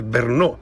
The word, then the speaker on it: Brno: no, no, pues eh, yo también siempre dije Breno y aquí resulta que se dice Bernó. Bernó. Eh, Bernó, sí, yo también me llevo al charco, siempre dije Breno, pero es Bernó. Vale, Bernó que se escribe BRNO, de ahí nuestra dificultad para pronunciarlo,